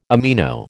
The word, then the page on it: amino: American English